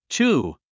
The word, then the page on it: chew: tʃúː; チュー